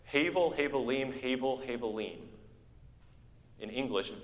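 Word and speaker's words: Havel (הבל): The name Abel in Hebrew is actually spelled with an H. It's Havel.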